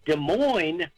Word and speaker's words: Des Moines: Like Des Moines is spelled like it should be Des Moines. – Right, right. – But you don't pronounce the S's.